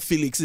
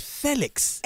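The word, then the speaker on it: Félix: Dude had to come out and say, my name is not Felix, it's Felix.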